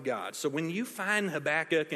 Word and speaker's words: Habakkuk: Some people pronounce it Habakkuk, but I'm going to go with Habakkuk all day because it just sounds cooler.